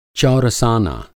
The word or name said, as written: चौरसाना